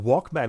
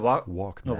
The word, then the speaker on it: Walkman: Bij Walkman wordt je meer gestuurd naar de, de Engelse uitspraak. – Ja, ja. Discman. Een Walkman. – Walkman.